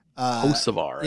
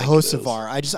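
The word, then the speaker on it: Hocevar: I always get his name wrong, even though I hear it all the time. – Hosovar. – Yeah, Hosovar. I want to say Hukavar.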